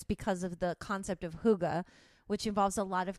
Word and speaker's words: hygge: Hygge. – Oh, I thought it was pronounced hygge. – Yeah. No, it's hygge? Spelled hygge, H-Y-G-G-E, but it is pronounced hygge.